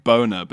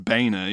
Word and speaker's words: Boehner: It's basically Mike Boner, but he claims it's pronounced Boehner, even though it's spelt with an O. And an E.